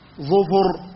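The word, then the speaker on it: ظُفُر: ظفر بضم الظاء وضم الفاء، وتنطق أيضا ظفر بضم الظاء وسكون الفاء، وتنطق ظفر بكسر الظاء وسكون الفاء، وتنطق ظفر بكسر الظاء وكسر الفاء.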